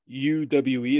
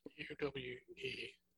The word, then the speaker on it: Uwe: U W E. – Um, U. – The V might be pronounced as, or the, the W might be pronounced as a V.